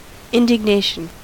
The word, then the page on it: indignation: American English